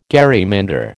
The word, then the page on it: gerrymander: American English